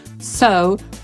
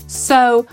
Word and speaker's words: sow: So to również siać albo zasiać. Dokładnie tak samo jak zasiali górale. Brytyjczycy powiedzą so, Amerykanie so i zapisujemy to słowo jako sow. S-O-W.